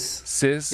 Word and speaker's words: SCIS: And your latest release, Size, is that pronounced correctly? S-C-I-S? – Oh, maybe Sizz. – Sizz? – You know? – Uh-huh. – Yeah, like sister album.